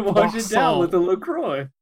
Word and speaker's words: La Croix: Is that how you say it? – La Croix. Yeah. – La Croix. – La Croix. No, it's La Croix. Like a croissant.